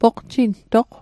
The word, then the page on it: poqjintoq: Mi'gmaq